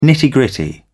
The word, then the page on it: nitty gritty: British English